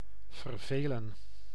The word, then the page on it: chagrin: /ʃə.ˈɡɹɪn/; [ʃə.ˈɡɹɪn]; /ˈʃæɡ.ɹɪn/